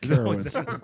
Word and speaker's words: Carowinds: It's pronounced Carowinds.